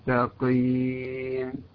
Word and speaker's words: مستقيم: فمثلا في قوله تعالى مستقيم، لا تقل مستقيم، لا، مستقيم.